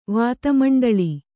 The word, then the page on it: ವಿಧುಮಂಡಲ: vidhu maṇḍala